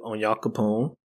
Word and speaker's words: Onyankopon: Um, they met up with Onyankopon.